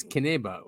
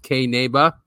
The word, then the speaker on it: Qinneba: Yes, Kineba, it's spelled Q-I-N-N-E-B-A. It's a weird spelling because it's ancient Aramaic and it means hemp in Aramaic. So Q-I-N-N-E-B-A, it's also pronounced Kineba, and it is a legal hemp marketplace.